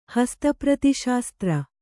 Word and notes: ಹಸ್ತಪ್ರತಿಶಾಸ್ತ್ರ: hasta prati śastra

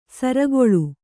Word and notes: ಸೂರುಗಲ್ಲು: sūrugallu

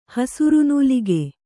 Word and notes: ಹಸುರುನೂಲಿಗೆ: kannada

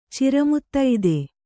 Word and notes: ಚಿರಮುತ್ತೈದೆ: kannada

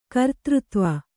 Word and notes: ಕರ್ತೃತ್ವ: kartřtva

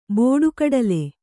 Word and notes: ಬಟ್ಟಗಡಲೆ: baṭṭagda'ale; Kannada

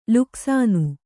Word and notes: ಲುಕ್ಸಾನ: Kannada

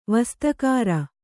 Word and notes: ವಸ್ತುಕಾರ: Kannada

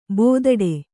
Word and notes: ಬೆದಡೆ: Kannada

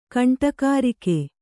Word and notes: ಗಂಡಗರಿಗೆ: gaṇḍagarige

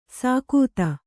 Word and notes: ಸಾಕೂತ: sākūta

